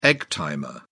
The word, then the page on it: egg timer: British English